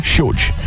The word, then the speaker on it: Shield: É com SH no começo. – SH? – É Child, né? Shield. – SH o quê? Como é que se escreve? – SHIELD. – Isso, Shield.